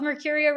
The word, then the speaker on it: Mercuria: Mercuria Running, that's your coaching group? – Yeah, you nailed the pronunciation. Um, we just did a video this week of how hard it is to pronounce.